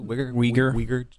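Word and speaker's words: Uygur: Uyghur.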